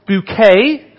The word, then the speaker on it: Bucket: This is Hyacinth Bouquet. Bucket woman, yes. Spelt bucket, pronounced bouquet.